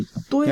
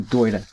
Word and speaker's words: Doida: Doida. You have to say it like that. Say it like Doida.